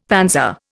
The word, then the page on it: panzer: Indian